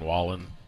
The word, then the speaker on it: Wallen: Is that a crude mashup of Waylon Jennings and Morgan Wallen? – What's that? – Morgan Whalen. – Is that not how you say it? – Wallen. – Wallen.